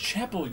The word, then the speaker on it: Chappelle: It's pronounced Chapel, you...